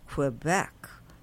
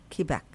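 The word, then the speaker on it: Québec: So from Montreal, we went to and it's not pronounced Quebec in Canada. It's called Quebec.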